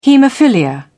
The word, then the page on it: hemophilia: British English